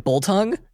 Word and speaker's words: biltong: Seeing it written out, it's spelled biltong, B-I-L-T-O-N-G. Here you pronounce it, it sounds like boltong to me.